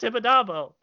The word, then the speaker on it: Tibidabo: It's pronounced Tibidabo.